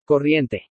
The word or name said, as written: CORRIENTE